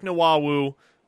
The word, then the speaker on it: Nwawu: That's the way it has been pronounced to me by folks that know him a lot better than I do. So we're rolling with Nwawu until we are proven incorrect.